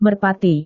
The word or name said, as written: merpati